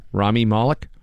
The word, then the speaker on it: Rami Malek: I have to say that everybody's freaking out saying that Rami, how do you say his name, Val? Rami Malek? – Yes.